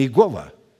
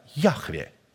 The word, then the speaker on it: Яхве: Но это не Иегова, это Яхве.